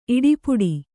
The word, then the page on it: ಇಡಪಿಡು: Kannada